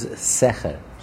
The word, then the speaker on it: secher: Rashi says that this does not mean reward, because the word, the Hebrew word for reward, is sechar. The pronunciation is char. Here, the pronunciation is secher.